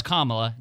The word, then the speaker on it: Kamala: I say Kamala. – It's Kamala. – That's the right one.